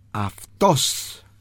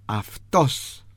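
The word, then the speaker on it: αυτός: Él, aftos. Él, aftos. A, F, T, O con acento, S, aftos. A, F, T, O con acento y S, aftos.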